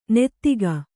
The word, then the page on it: ನದುಗು: nadugu